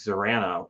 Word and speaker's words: Zarana: And so whenever you say things like the Deke Zarana, well, it's kind of Order of Battle Zarana. – Deke who, sorry? – What? – Deke who? – Zarana? – I don't think you're saying it correctly. I believe it's Zarana!